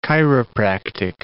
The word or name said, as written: chiropractic